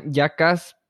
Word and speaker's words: JVCKAZ: Em, se llama Yacas, pero se escribe JV, o sea, en vez de una A es una V, la primera. Y es de que J-V-C-K-A-Z.